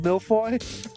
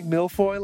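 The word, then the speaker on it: Malfoy: Can we just say, killed the pronunciation of milfoy? – Can we just say that? We can. – We are allowed to congratulate me for saying milfoy like a champ.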